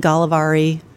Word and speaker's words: Gällivare: It's in Gällivare. I don't know if I pronounced that right. Sweden. – That sounded good.